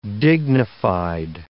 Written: dignified

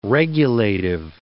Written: regulative

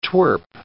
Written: twerp

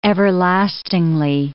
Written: everlastingly

